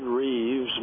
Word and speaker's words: Rives: All right, it's David Reeves Ministries, and it's spelled R-I-V-E-S, David Reeves Ministries.